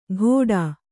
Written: ಘೋಡಾ

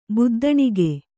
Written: ಬುದ್ದಣಿಗೆ

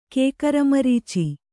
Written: ಕೇಕರಮರೀಚಿ

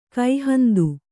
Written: ಕೈಹಂದು

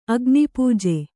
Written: ಅಗ್ನಿಪೂಜೆ

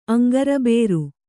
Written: ಅಂಗರಬೇರು

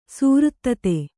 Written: ಸುವೃತ್ತತೆ

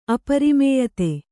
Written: ಅಪರಿಮೇಯತೆ

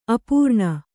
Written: ಅಪರ್ಣ

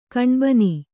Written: ಕಣ್ಬೇನೆ

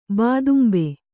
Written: ಬಾದುಂಬೆ

